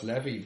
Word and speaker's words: Levy: And this is Alex Levey's defending. – No, that's Levy. Levey was Thomas Levey. – Yeah, that's L-E-V-E-T. Yeah. This is L-E-V-Y.